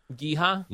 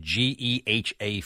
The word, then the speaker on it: GEHA: Geisha? – Yeah, I that's how you pronounce it. Geha Field at Arrowhead Stadium.